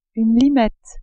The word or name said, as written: limette